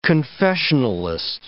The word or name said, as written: confessionalist